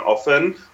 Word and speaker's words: often: It's like people that pronounce the T in often. I just can't handle it.